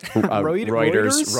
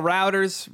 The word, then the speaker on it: Reuters: Reuters? Reuters, yeah. – I call it Routers. Reuters, wonderful name here.